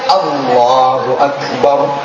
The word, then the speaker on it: Allahu Akbar: Lek takbir mestinya Allahu Akbar. Ketahuan Allahu Akbar. Abis ba ada alifnya, itu artinya udah jauh berubah.